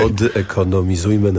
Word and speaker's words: Odekonomizujmy: Autor manifestu pod hasłem Odekonomizuj... Przepraszam, trudno się to wymawia, ale tym bardziej przykuwa uwagę. – Okay. – Odekonomizujmy nasze życie.